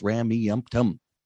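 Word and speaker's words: Rameumptom: Four syllables, Ramiumptum. Yep, four syllables.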